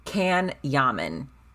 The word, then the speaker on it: Can Yaman: His name is it's spelled like can something. As in like a can of Pepsi. Can Yaman, all A's, CanYaman.